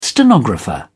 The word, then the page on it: stenographer: British English